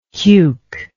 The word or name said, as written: cuke